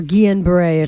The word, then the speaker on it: Guillain-Barré: Guillain-Barré. – Guillain-Barré. – Or Guillain-Barré, if you want to go with the French pronunciation. – Guillain-Barré.